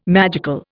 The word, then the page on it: magical: Indian